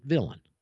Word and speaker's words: villein: And you can go to Black's Law Dictionary, and it's got a French spelling, V-I-L-L-E-I-N, villein. But the English pronounced it villain.